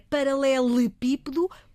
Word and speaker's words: paralelepípedo: Portanto, é paralelepípedo porque provém do grego e em grego é assim, paralelepípedo. Não se esqueçam, a forma como pronunciamos tem que ver com a origem da palavra.